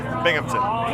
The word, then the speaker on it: Binghamton: Hey, this is Tim Norton from the band OK Go, and you're listening to WHRW Bimington. Is that right? – Binghamton. – Bimington. How do you say it? – Binghamton. – Binghamton. – Yeah.